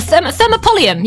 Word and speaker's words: thermopolium: So it's called a, I'm going to try and say this right, a thermopolium. A thermopolium. Yeah, that, nailed it.